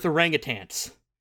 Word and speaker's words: orangutan: However, we believed it's pronounced orangutan. It's derived from the Malay words orang, meaning man, and utan, meaning forest.